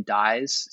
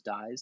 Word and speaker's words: Dies: Last name Dies, spelled like a sentence, Nicholas Dies, D-I-E-S.